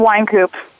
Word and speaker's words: Wynkoop: Yes, it's Wynkoop.